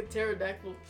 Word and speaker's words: pterodactyl: You sound like a pterodactyl. – The fuck? Did you know the P in pterodactyl is silent? – Patera?